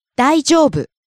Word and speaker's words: 大丈夫: 大丈夫。大丈夫。 – Rises on the second syllable, e, falls on the second to last syllable, u. – 大丈夫。